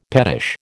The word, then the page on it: pettish: American English